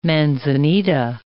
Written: manzanita

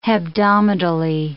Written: hebdomadally

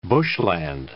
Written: bushland

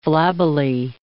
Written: flabbily

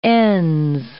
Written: ns

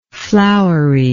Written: floweriness

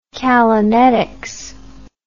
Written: callanetics

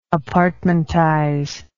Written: apartmentize

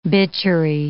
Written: bitchery

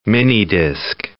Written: minidisc

